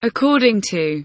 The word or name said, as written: according